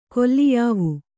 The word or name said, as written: ಕೊಲ್ಲಿಯಾವು